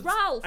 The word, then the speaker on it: Ralph: It's Ralph.